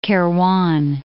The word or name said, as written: Kairouan